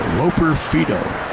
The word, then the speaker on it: Loperfido: Loperfido, L-O-P-E-R. And then F-I-D-O, like Fido. But pronounced Fido. Loperfido.